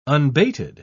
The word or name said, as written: unbated